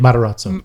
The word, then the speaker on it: Matarazzo: Matarazzo.